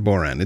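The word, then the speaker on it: bodhrán: Now, a lot of people are going to say, well, isn't it Bodhran? No, I actually have it on good authority that it's actually pronounced Boron. It's supposed to be. The D is silent. I made the mistake. I was calling it a Bodhran for a long time.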